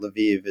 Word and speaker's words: Lviv: You know, by the way, my grandmother was born near Lviv, or as you call it, Lviv. I just found out from you that Lviv is probably a better pronunciation.